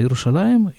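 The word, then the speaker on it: Иерушалаем (ירושלים): Но так оно вот произносится. Иерушалаем, Ир-а-Атика. Иерусалим – старый город.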